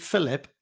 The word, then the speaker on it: Phylip: Yeah, so I'm fortunate is my father named me Phylip after the evangelist in the Bible, Phylip.